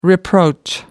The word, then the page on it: reproach: American English